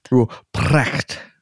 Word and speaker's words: Ruprecht: Ruprecht. I don't think you're supposed to roll the R if it's in the beginning of a word.